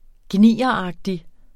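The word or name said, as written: gnieragtig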